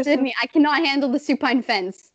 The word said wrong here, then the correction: suspense: You mean the suspense? – Wait, did I say that right? Did I mess that up? – Yeah. – What is it? Wait. Suspense. – Suspense, weirdo.